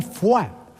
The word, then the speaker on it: foie: Foie, que es una palabra francesa que a mí siempre me ha llamado mucho la atención porque se escribe foie... Sí. Y se pronuncia fuá. Fíjate, la O, la I y la E se escribe. Y luego se pronuncia con la U.